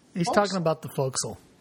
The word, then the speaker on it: fo'c'sle: Oh, and by the way, you motherfuckers, it's pronounced fo'c'sle. If I hear somebody say fossicle one more time, I'm fossicle.